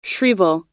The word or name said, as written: shrieval